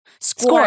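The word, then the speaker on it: SCOIR: Score. Score. Thank you. That's it. Score. – Square. – Score spelled S-C-O-I-R, I think.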